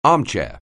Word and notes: armchair: anglais

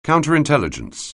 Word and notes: counterintelligence: anglais

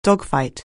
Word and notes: dogfight: anglais

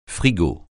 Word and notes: frigo: francais

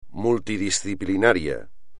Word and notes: multidisciplinario: espagnol

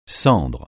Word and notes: cendre: francais

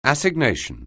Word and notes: assignation: anglais

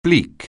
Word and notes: bleak: anglais

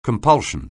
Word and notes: compulsion: anglais

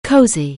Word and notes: COHSE: anglais

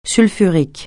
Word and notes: sulfurique: francais

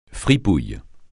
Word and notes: fripouille: francais

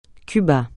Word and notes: Cuba: francais

